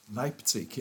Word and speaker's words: Leipzig: Leipzig.